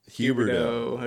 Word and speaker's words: Huberdeau: I was thinking Jonathan Huberdeau. I don't know how to say his last name, but he might be a fourth line. – Huberdeau.